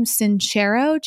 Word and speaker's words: Sincero: Sincero, Jason? – Yep.